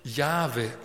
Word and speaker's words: Jahwe: Eigentlich im Hebräischen nur vier Buchstaben, J-H-W-H, die Selbstlaute, die kennt man eigentlich gar nicht genau. Aber mit größter Wahrscheinlichkeit, so was man dann herausfinden konnte durch die Sprachwissenschaften, spricht man ihn tatsächlich Jahwe aus.